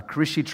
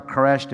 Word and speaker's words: Quraysh: We find that he was born into a tribe, the Quraishi tribe, or Quraysh, depends how you pronounce it.